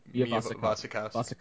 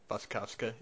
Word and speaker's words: Wasikowska: Is it Mia Wasikowska? – Wasikowska, yeah.